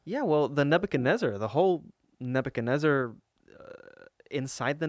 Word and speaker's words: Nebuchadnezzar: I always, the ship name, I always trip Nebuchadnezzar.